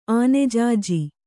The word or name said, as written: ಆನೆಜಾಜಿ